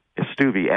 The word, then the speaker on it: Stueve: It's Stuvey, S-T-U-E-V-E. And I have to apologize to all of our friends in the Midwest. That's not how it's pronounced back there.